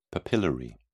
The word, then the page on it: papillary: British English